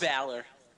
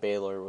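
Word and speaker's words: Bálor: Balor. – Same shit. He pronounces it Baylor with his fucking accent, so. I'm just saying what he says. – No, he says it like, I'm Finn Balor.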